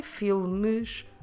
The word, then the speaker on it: filmes: We could pronounce it very slowly, filmes, but what we normally say is filmes.